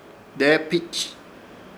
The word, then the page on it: Deepich: Ishir Chamacoco